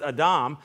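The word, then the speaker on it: Adam (אָדָם): It's Adam, but it's actually pronounced Adam.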